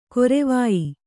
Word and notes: ಗರವೊಯ್: garavoy

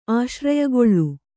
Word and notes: ಆಶ್ರಯಗೊಳ್ಳು: kannada

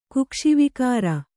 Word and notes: ಕುಕ್ಷಿವಿಕಾರ: Kannada

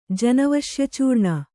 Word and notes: ಜನವಶ್ಯಚೂರ್ಣ: kannada

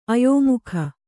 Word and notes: ಅಯೋಮುಖ: Kannada

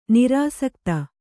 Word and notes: ನಿರಾಸಕ್ತ: kannada

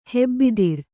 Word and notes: ಹೆಬ್ಬಿದಿರು: kannada

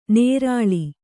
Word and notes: ನೇರಾಳಿ: nērāḷi

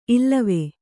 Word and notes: ಇಲ್ಲವೆ: Kannada

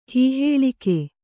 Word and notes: ಹೀಹಾಳಿಕೆ: kannada

